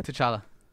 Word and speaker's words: T'Challa: How do you say that? – T'Challa. – T'Challa? – It's like the T apostrophe.